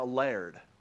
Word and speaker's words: laird: See, in Scotland, traditionally, you're headed by a laird, which is just how they pronounce lord.